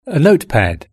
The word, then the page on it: a notepad: British English